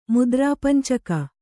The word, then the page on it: ಮುದ್ರಾಪಂಚಕ: mudrāpancaka